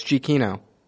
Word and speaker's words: Giacchino: He was like, "You're saying it wrong. It's Giacchino." Giacchino. – Yeah. – Is that... GIA, so it looks like you're supposed to say something, but I, it...